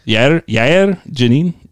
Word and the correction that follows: Jain: Jain. – Jain. Jain. Yair Jain. – Yair Jain. That's right.